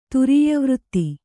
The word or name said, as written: ತುರೀಯವೃತ್ತಿ